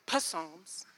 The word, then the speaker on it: Psalms: And my mom, I had mentioned it to her, and she's like, the P is silent. And I was like, oh. And ever since then, every time I open up that book, I'm like, oh, it's Psalms.